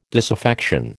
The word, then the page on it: disaffection: American English